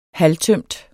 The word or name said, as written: halvtømt